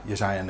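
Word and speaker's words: Jesaja: Jezaja 9. Zeg ik het goed? Ja, Jezaja 9.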